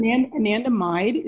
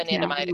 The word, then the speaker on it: anandamide: Well, I'm just thinking about ananda and the anandioid receptor. Say it again. I may have mispronounced it. – Anandamide is the anandamide, excuse me.